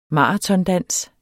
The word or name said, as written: maratondans